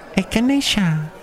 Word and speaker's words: echinacea: Take some echinacea. – Oh, I like the sound of that word. How do you say it? – Echinacea.